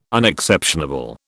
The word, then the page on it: Unexceptionable: British English